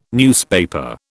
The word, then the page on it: newspaper: British English